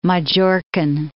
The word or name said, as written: Majorcan